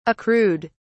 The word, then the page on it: accrued: British English